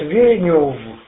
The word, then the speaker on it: Венев: Город Венев, он читается как сзади, так и, ну, в общем, с боков все равно читается одно и то же.